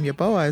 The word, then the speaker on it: Yeboah: And my last name, Yeboah, is spelled Y-E-B-O-A-H.